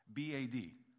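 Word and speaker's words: bad (בד): B A D. That's how it's spelled. That's how you say it. It's bad.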